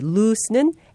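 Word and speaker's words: loose: 발음할 때 L-O-O-S-E, lose는 S로 끝납니다. 반면에 L-O-S-E, lose는 Z로 마무리합니다. lose.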